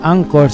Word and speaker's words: anchor: Para los que no hablan inglés, la palabra Anchor se escribe Anchor. A-N-C-H-O-R, Anchor. En inglés se dice Anchor pero en español sería Anchor, Fe y Esperanza.